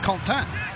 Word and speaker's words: Quentin: I'm a Quentin, which of course is a French word for the fifth, which is pronounced Contin.